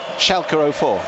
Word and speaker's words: Schalke: Schalke 04. – Well, Schalke, it's them.